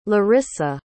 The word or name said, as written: larisa